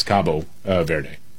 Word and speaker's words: Cabo Verde: Yeah, Cape Verde, or in Portuguese, it's Cabo Verde.